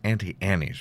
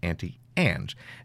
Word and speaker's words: Auntie Anne's: By the way, I lived most of my life thinking that was Auntie Annie's pretzels. And maybe that is how you pronounce it. I don't know. That's how I've always learned it. But when you look at it, it's Auntie Anne's.